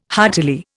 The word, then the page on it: heartily: Indian